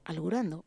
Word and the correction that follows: elucubrando: ¿Será que tú quieres decir elucubrando? – ¡Eso!